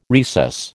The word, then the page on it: recess: American English